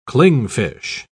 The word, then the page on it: clingfish: British English